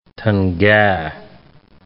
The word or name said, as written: tanga